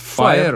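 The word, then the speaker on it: Fyre: Фестиваль, который называется Fire. Fire, или... Fire он Fire, называется. Да, да. просто он вот пишется я... немножко по-другому через, да. через Y или через Y.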